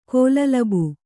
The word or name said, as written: ಕೋಲಲಬು